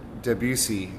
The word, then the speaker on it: Debussy: I mean, I'm not sure I'm pronouncing it exactly correctly either, but Claude Debussy, and it's spelled D-E-B-U-S-S-Y.